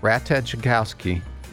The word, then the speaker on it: Ratajkowski: I know it's early, but they've got this thing out like, oh, this model and actress named Emily Ratajkowski. – I think the J is silent.